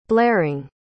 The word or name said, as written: blaring